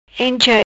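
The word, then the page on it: Encha: Patua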